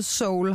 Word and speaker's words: Seoul: Der er lige et E i, som vi udtaler Seoul, har du lært mig i dag.